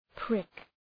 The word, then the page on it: prick: prık